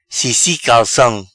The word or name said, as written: Cissi Calcam